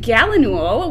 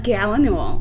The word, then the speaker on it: gallinule: And a common gallinule, which was the first spotted by Chris Chine. – How do you pronounce that again? – Gallinule.